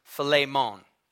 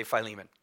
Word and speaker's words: Philemon: And so we read here, Paul, a prisoner of Jesus Christ, and Timothy, our brother, to, and the name here is actually pronounced Philemon. But that's just weird, so we're going to say Philemon.